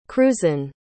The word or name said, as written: cruzan